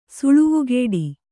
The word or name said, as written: ಸುಳುವುಗೇಡಿ